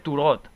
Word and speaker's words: Tureaud: Se llama Lawrence Turod. – Turod. – Se escribe Tureaud, ¿vale? Se pronuncia en francés Turod.